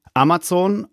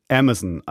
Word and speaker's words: Amazon: Da stehen zwei Möglichkeiten. Amazon, also deutsche Aussprache, und Amazon, also amerikanisch-englische Variante. Beides geht und beides werdet ihr hier in diesem Podcast auch hören.